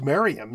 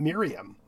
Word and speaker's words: Merriam: And it was the company was founded by George Merriam, and he sent us the name showing us that little squiggly, you know, backwards E sound, which indicates it's pronounced Merriam, not Miriam.